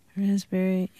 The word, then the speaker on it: raspberry: K-N-E-E-L, Neil. – Raspberry, answer. – Silent letters. – Yes, silent letters. – Oh, yeah, raspberry is... – The silent S... – P. – Or P. Sorry. – Silent P, right?